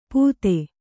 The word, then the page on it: ಪೂತೆ: pūte